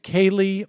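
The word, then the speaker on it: Kayleigh: This is Kylie McEnany. Kaylee McEnany. I'm sorry.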